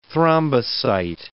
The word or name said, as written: thrombocyte